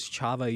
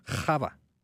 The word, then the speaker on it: Chava: And I was like, what in the world does Chava even mean? And it turns out I was saying it wrong. It's Chava.